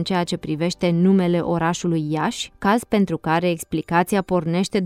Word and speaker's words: Iași: În primul rând, din cauză că localnicii, ca toți moldovenii, nu pronunță înmuiată nici consoana ș, forma curentă în vorbire este Iași, deși se scrie Iași cu i final.